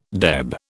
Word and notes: deb: British English

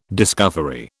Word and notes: discovery: British English

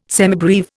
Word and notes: semibreve: Indian

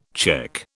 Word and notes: cheque: British English

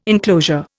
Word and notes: inclosure: Indian

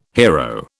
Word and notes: hero: British English

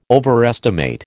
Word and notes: overestimate: American English

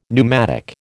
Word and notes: pneumatic: American English

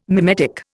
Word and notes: mimetic: Indian

